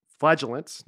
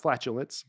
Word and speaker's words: flagellants: They're called flagellants, not flatulence, but flagellants, I think it's how it's pronounced.